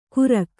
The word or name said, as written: ಕುರಕ